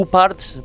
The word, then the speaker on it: OOPArts: Un objeto que, puede ser sorpresivo, hay que decir que yo para, para este capítulo de los OPA o Uparts, pronunciado de forma más, más correcta,